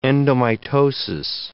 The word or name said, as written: endomitosis